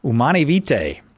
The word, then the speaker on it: Humanae Vitae: Humanae Vitae. Did I pronounce it right, or should I say Humanae Vitae? Humani. – What you said was okay.